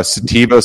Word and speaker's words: sativa: And so that would then mean some of the information you have here about hemp comes from cannabis sativa. Is that how you say it? – Yeah. Sativa, some people call it sativa.